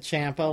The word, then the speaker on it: Ciampa: I've always said Ciampa, like Tampa. So then I was curious to see how they would say it on the NXT broadcast last night. And everybody says Ciampa, so I'm going to stick with Ciampa.